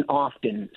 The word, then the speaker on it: often: So even though words like listen, the T is silent, soften, it came back in often. So it was often, and then the T just crept in when people would see it in writing.